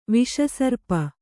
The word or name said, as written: ವಿಷಸರ್ಪ